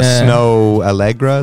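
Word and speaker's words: Snoh Aalegra: Uh, Snoh Aalegra is how you say it.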